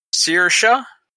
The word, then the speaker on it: Saoirse: In the end, no one was able to keep up with the pole sitter Saoirse Fitzpatrick from Ireland.